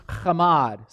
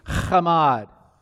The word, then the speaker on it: chamad: The Hebrew word is chamad. Say it with me, chamad. If you say it right, the person in front of you should have spit on the back of their head. Chamad, chamad. Now, chamad is almost like commode, but not quite.